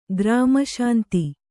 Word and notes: ಗ್ರಾಮಶಾಂತಿ: grāma śanti

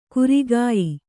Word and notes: ಕುರಿಗಾಯಿ: kurigāyi

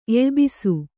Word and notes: ಎಳ್ಬಿಸು: eḷbisu

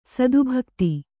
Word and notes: ಸದ್ಭಕ್ತ: sadbhakta

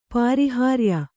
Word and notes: ಪರಿಹಾರ್ಯ: Kannada

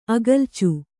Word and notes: ಅಗಲ್ಚು: agalcu

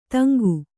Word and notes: ತಂಗು: Kannada

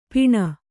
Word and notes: ಪಿಣ: piṇa

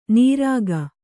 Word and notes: ನಿರಖ್: nirakh

